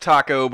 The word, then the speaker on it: Tacko: no, it's spe- no, it's pronounced taco, but it's not spelled like taco.